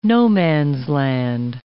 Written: no-man's-land

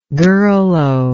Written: girlo

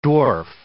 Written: dwarf

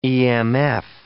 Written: emf

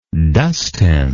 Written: dustin

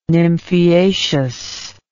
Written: nymphaeaceous

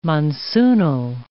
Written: monsoonal

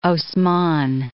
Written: Osman I

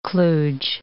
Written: kludge

